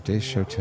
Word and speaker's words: Desotell: How do you pronounce it? – Desotel. – D-E-S-O-T-E-L-L.